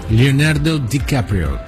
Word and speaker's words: Leonardo DiCaprio: Leonardo DiCaprio. – Sí, sí. – ¿Lo pronuncié bien? – Sí, sí, perfecto.